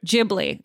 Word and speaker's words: Ghibli: And somebody suggested, and again, I'm going to say it wrong, Studio Ghibli or Studio Ghibli. I don't remember how it's pronounced. Ghibli. Okay, Mike says.